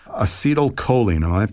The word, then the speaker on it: acetylcholine: and that is acetylcholine. Am I pronouncing that correctly? – You're doing it perfect. Well done.